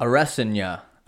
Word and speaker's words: Adesanya: How'd you say to pronounce it now? – Arestanya. I hope I'm right on that, but I did hear that from multiple guys in the know.